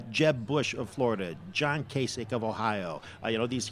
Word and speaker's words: Kasich: After Elsa, I'm trying to figure out what rhymes with Kasich. – LASIK eye surgery, maybe?